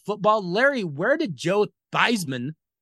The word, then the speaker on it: Theismann: This guy used to be known as Joe Theismann when he was in high school, but in college, he changed the pronunciation to Theismann because it rhymed with Heisman.